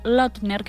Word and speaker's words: Lautner: Este que escuchábamos era Taylor Lautner, que no sé cómo se pronuncia Sí, esto.